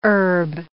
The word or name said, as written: herb